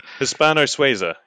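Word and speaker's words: Hispano-Suiza: So you can see up here, you have the 303 Brownings and the, how do you pronounce it? Hispano Suiza? You know, the British ones that don't have a British name. – Right. – Hispano Suiza. Yeah, that's them.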